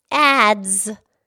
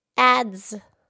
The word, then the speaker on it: adze: And then there's this other thing called, it's called a broad adze. Adze. – Right. It's spelled A-D-Z, or it's also spelled A-D-Z-E.